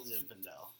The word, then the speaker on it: Zinfandel: White Zinfandel. – Zinfandel? – Zinfandel. – Yeah. – Is that how you say it? – Yeah, you got it. You didn't say it the first time.